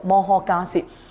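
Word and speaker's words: 摩诃迦叶: 咁迦涉因为佢系一个嘅名称嚟嘅，梵文译过嚟嘅时候，我哋就将呢个字读成个涉字，所以读摩诃迦涉。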